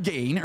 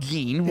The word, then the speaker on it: Gein: Pretty sure it's Ed Gein or Ed Gein, whichever way you want to pronounce it.